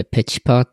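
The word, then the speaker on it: PHPer: これ、ペチパーって読むんですね。 – そうですね。これは誰が言い始めたのかわかんないけど、 PH パーではなくてペチパーですね。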